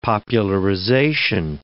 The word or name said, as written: popularization